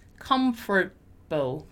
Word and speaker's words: comfortable: Comfortable, comfortable, comfortable, right? So if you speak it inwards, you kind of eat the letter, right? Also notice that we don't pronounce the letter A. Comfortable, comfortable, comfortable, right?